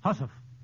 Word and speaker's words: housewife: Mr. Adams, how did housewife become hussy, and what was the original pronunciation of housewife? Mr. Kieran? – Hussif. – Yes, hussif is right, and I guess they just left off the last part. Now, how about the word wench? Wench, W-E-N-C-H.